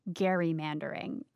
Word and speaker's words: gerrymandering: And yes, he calls gerrymandering gerrymandering. And he knows what he's doing here. Remember episode one? Gerrymandering is named after Elbridge Gerry. Reagan stands by the hard G.